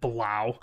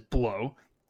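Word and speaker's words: Blough: We're The Lions is looking like they will start David Blau, easily mispronounced as Blow, who surprisingly didn't blow last week.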